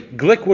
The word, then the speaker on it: Glueck: Gluck, G-L-U-E-C-K. At the age of 23, what's that? It's pronounced Glick? – I know it's on Charlie. I think it's pronounced. Charlie pronounced it Glick. – Okay, thank you. At the age of 23, Glick